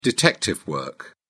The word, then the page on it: detective work: British English